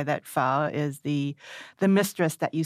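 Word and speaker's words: phở: Well, if you want to impress a Vietnamese speaker, you would say pho. – Pho. – Yeah, sort of like languidly, sort of romantic, you know.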